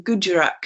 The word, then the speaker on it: Gujarat: That was discovered off the coast of Bet Dwarka, which is an island on, I think it is the Guru, let me just check my pronunciation, on the Gujarat coast.